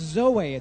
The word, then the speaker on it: zoe: And in John 10:10, the word life that Jesus uses is actually a word that is pronounced zoe. It's a Greek word, zoe.